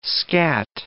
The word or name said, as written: scatt